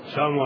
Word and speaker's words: السلام: نذكر لهم أنه لا بد أن تخرج اللام، لا بد أن تنطق باللام. أي نعم، يقول السلام عليكم، السلام عليكم.